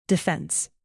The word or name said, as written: defense